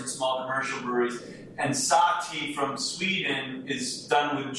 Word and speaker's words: SahTea: We did a very early version of SahTea. And by the way, our version is spelled S-A-H-T-E-A, so that the word tea is in there.